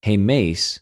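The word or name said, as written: ἡμεις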